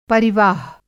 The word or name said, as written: परिवाह